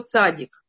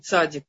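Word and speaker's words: цади (צדי): Это буква цадик. Эм, Что мы знаем про цадик? Э, что, в принципе, цадик, это сегодня мы произносим цадик, эту букву, а вообще она читается как цади.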